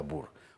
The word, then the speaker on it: Boer: Broek, que se pronuncia Brook, porque el diptongo OE en neerlandés se pronuncia U, como en Boer, que se pronuncia Bur.